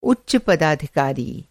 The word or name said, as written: पदाधिकारी